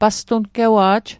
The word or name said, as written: pastungewa'j